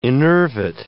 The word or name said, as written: enervate